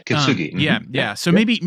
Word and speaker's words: kintsugi: But in Jesus, all of this is moving us towards something beautiful, which you bring up the beautiful picture of, is it kintsugi? I don't know if I'm saying that correctly. – Yep, kintsugi.